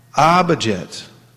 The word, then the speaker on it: abhajet: And abhajet. Abhajet, ah means fully. It's a long A.